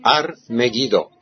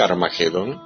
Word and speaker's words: Armagedón: En el valle de Josafat, como dice el profeta Joel, o en Armagedón, Ar-Megiddo, que es un lugar físico de Israel, en el lugar de Megiddo, Ar-Megiddo, o en castellano se dice Armagedón, que es un lugar físico que está en una llanura de Israel.